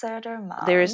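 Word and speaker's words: Södermalm: I'm again pronouncing is it Söndermalm? – Yes, Söndermalm.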